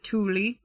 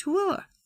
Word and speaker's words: Tooele: Some people pronounce it Tooele. I'm just going to throw that out there. It is spelled weird. It's pronounced Tooele. Doesn't make sense, but that's how it's pronounced.